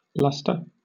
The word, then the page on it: lustre: British English